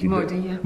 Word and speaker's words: Modi: Modi, how is it called? How do you pronounce it? – Modi, yeah.